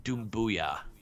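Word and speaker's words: Doumbouya: I think it's pronounced Dumbuya. So it's like Sekou Demboya.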